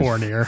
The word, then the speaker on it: Fournier: How are you going to pronounce that one? Uh, uh, well, he's got to do something first. Uh, Evan Fournier. I think Fournier. – Fournier, right? Yeah.